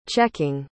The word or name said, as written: checking